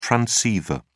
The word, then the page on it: transceiver: British English